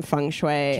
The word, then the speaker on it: feng shui: How do you say it? Is it feng shui? – Feng shui. – Feng shui. – Yes.